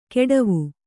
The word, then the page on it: ಕೆಡವು: keḍavu